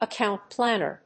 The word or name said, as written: account planner